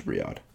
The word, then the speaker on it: Riyadh: It's Riyadh.